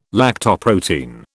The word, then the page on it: lactoprotein: British English